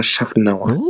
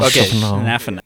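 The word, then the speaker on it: Szafnauer: Otmar Schaffenauer. – Schnaffen. – Otmar Schaffenauer.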